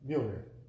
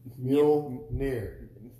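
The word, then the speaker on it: Mjölnir: Mjolnir. – Mjolnir.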